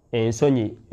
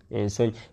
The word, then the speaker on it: Ensonyi: So that in Luganda, that's how it's pronounced. Ensonyi, modesty. Ensonyi.